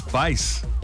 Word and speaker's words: Bass: Lance Bass. – Bass. I think it's pronounced bass as in like a bass fish.